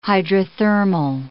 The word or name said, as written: hydrothermal